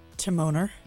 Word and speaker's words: Timoner: Did I say your name wrong? – It's okay. – Say it. – Timoner. – Timona. – Timoner. – Timoner. – Yeah.